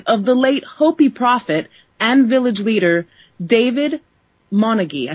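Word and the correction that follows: Monongye: I hope I'm pronouncing his name right. – Manonya. – Anyway, say it again. – Manonya. – I definitely was not pronouncing that right.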